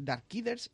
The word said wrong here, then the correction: Darksiders: ah, Genesis, eh, ¿lo he dicho bien? Darksiders, el Darksiders, Darksiders sí, Genesis, eso.